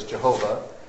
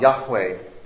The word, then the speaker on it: Yahweh: Which is the name, it's been mispronounced over the years as Jehovah. This is the actual name of God, Yahweh.